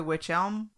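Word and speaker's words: Wych Elm: Well, it's actually witch elm. I don't know why I kept saying witch helm. – Because it's spelled that way. – Well, I kept helm. in my mind, witch helm, but it's witch elm. – Like elm tree.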